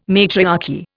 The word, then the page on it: matriarchy: Indian